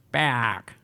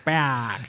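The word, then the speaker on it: Peák: Dus ik heb gevraagd, ja, hoe spreek je die naam eigenlijk uit? En die hebben dus tot in den treuren dat weekend alleen maar tegen me Paak gezegd. Paak.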